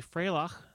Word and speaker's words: Freilach: Got a call from a fellow who mentioned that it is actually Freilach, so I will pronounce it correctly from now on.